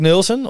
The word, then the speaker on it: Nolson: Spreek je trouwens uit als Nolson of Nulsen? – Eigenlijk Nulsen, omdat er zo'n streepje door de O zit.